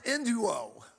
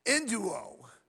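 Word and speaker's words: enduo (ἐνδύω): It's spelled E-N-D-Y-O, but it's pronounced enduo, enduo, enduo.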